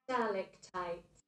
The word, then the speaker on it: stalactites: When you go to a cave and you see all the stalactites. Is that how you say it? Stalactite? Sounds wrong. Wait, let me go on Google here. – Stalactites. – All right, stalactites.